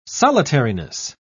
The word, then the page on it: solitariness: British English